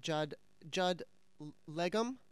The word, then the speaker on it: Judd Legum: is it Judd Legum? – Yeah.